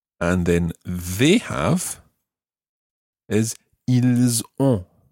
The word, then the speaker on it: Ils ont: And that's spelled O N T. Ils ont. – Ils ont. – and make sure that you you sort of make that an on sound as opposed to an on sound on